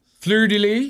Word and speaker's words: fleur-de-lis: Fleur-de-lis? – Yeah.